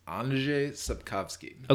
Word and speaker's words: Andrzej Sapkowski: Apparently it's Andrzej Sapkowski.